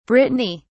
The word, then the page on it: brittany: British English